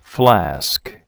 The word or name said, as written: flask